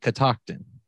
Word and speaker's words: Catoctin: And did I say it right? Catoctin, right? – You absolutely did.